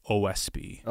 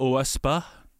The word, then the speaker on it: Oahspe: O-A-H-S-P-E, Oahspe. – Okay. – I've heard also Oahspe. – Uh-huh. – As a pronunciation, I'm sticking to Oahspe, and there we go.